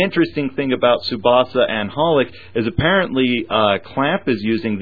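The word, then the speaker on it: xxxHolic: No, it's actually just pronounced Holic because the triple X part at the beginning isn't like triple X, like, you know, flashing neon sign triple X, but it means a variable.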